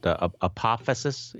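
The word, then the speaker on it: apophasis: Is it apophysis? – Yes, yes.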